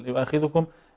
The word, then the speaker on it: يؤاخذكم: لا يؤاخذكم، مثلا أول آية أول كلمة منها في الثاني كلمة يؤاخذكم، كثير من الناس يعني يسكن الذال من يعني إذا أسرع بنطق يؤاخذكم. أيوة أحسن، ده خطأ منتشر جدا.